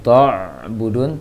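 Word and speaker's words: ta'budun: Kemudian ta' budun, jangan ta' budun, tapi ta' ta' budun.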